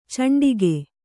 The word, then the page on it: ಚಂಡಿಗೆ: caṇḍige